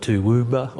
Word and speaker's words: Toowoomba: People call it Toowoomba or Toowoomba.